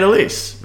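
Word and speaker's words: Alyss: You say it Elise, right? – Elise. – It's A-L-Y-S-S. And I'm reading it, I'm like, yeah, it's Elise.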